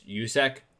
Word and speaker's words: Juszczyk: Like I had it set on how to pronounce his name. It's Juszczyk, the fullback number 44, the fullback for San Francisco.